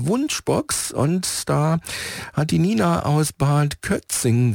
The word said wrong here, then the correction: Kötzting: geschrieben. Kötzting, so muss ich es richtig aussprechen. Das ist in oberen Bayerischen Wald und sie schreibt, wir möchten uns bei Heinz für den unvergesslichen Familienurlaub auf der Insel Rügen bedanken.